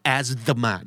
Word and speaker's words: asthma: asthma นะครับ asthma asthma มี th แต่ไม่ออกเสียง th